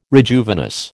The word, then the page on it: rejuvenesce: American English